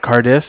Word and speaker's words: Cardiff: Bank of Carters? – Cardiff. It's spelled C-A-R-D-I-F-F.